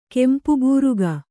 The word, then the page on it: ಕೆಂಪುಬೂರಗ: kempu būraga